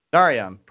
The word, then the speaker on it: Narayan: Narayan, how do you say your last name? And welcome to the show. – Thank you. Thank you, Eric. You got it right. Karthik Narayan. – Narayan, perfect, perfect.